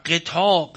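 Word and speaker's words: غطاء: مدرکات که ادراک میکنیم، حالا تلفظ صحیحش، و قطاع، قطاع یعنی پرده، قطاعی میان او و خلقش زده نیست.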